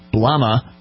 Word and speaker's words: Lama: Dalai, meaning ocean, and the Tibetan word Lama, with a silent B, Lama, meaning chief or high priest.